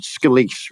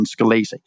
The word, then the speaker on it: Scalise: You're right, with the exception that you've given Mr. Scalise a bit more of an Italianate last name than he actually pronounces it. He just pronounces it Scalise rather than Scalise.